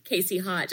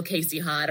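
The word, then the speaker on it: Kasey Hott: And then I, and then on all my things, I think I'm just Casey Hott, just my name. It's pretty boring. I'm not like the real Casey Hott or anything like that.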